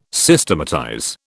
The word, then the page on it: Systematize: British English